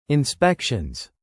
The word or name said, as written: inspections